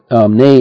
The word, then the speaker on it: neigh: Nay is N-E-I-G-H. Naam, but the GH is not pronounced, so it's pronounced, yani the sound of the word is nay or whinny, W-H-I-N-N-Y.